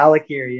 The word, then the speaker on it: Alikirii: yeah, Alikirii.